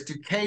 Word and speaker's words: Duque: And the nice thing is Duque is very clear. It's two syllables.